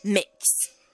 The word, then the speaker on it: Mx: Now, Mix, MX, is a gender-neutral title given to those who choose not to identify with the gender, and it's pronounced, once again, as Mix.